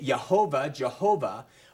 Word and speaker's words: Jehovah: And what that means then is, I'm not going to get too into this, but just let you know, we don't really know how to pronounce this word because we don't have vowel markings. Now, the Hebrews have markings that they put between the consonants of their letters, and it would help you understand how to pronounce the words. But we don't have vowel markings for the YHWH. We will say Yahweh, or we will say Jehovah, but that is the proper name for God, and it's really not known exactly what it is.